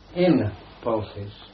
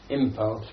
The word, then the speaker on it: impulse: We change the N to M before the labial, like P or V. So we say impulse instead of in pulse, simply because P is pronounced on the lip. It is easier to say impulse than in pulse.